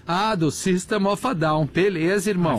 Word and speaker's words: System of a Down: Lembrei daquela entrevista da Sandy, que ela fala que, que a pronúncia correta é System of a Down.